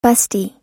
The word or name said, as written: bustee